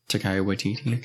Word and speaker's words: Taika Waititi: I don't know what kind of stuff like Taika Waititi. Did I say that right? – Taika Waititi. Okay.